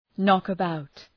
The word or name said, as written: knock about